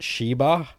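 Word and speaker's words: shiba (씨발): The Korean word for fuck is pronounced shiba, and I got the gist of what it meant through context before officially asking my Korean friends for the translation.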